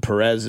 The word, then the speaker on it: Perez: 816, the way they're pronouncing Perez is the authentic Spanish pronunciation.